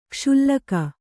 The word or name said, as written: ಕ್ಷುಲ್ಲಕ